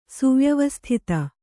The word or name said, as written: ಸುವ್ಯವಸ್ಥಿತ